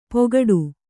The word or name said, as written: ಪೋಗಾಡು